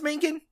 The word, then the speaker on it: Mangin: Wait, is it pronounced Mangin? – Yes, it is.